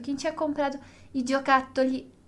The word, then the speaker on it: giocattoli: Giocattoli, giocattoli. Questa è la pronuncia giusta.